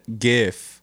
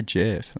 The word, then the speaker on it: Jif: Before we started recording, said GIF instead of Jif, even though it is spelled J-I-F. – I know how to pronounce it. – He pronounced it GIF. He's like, is it GIF or Jif? I'm like, I knew it was Jif.